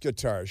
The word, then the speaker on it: Qatar: And here's how to pronounce Qatar. It's pronounced Qatar.